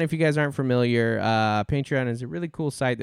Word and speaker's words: Patreon: It's either two or three syllables, depending on how you pronounce it. – Right. – Patreon. How do you hit that with two or three syllables? – Patreon.